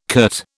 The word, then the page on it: curt: British English